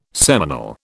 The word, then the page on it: seminal: British English